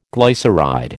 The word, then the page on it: glyceride: American English